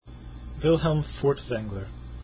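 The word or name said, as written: Furtwängler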